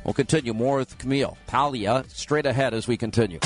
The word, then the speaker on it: Paglia: Camille Paglia is with us. And even though it's spelled P-A-G, it's Paglia. Did I say it right? I think I did. – Paglia.